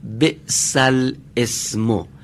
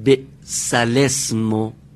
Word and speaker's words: بئس الاسم: اینطور که نوشته شده باید بگیم بئس الاسم، اما به خاطر یک نکته‌ای که اینجا هست ما باید بگیم بئس الاسم.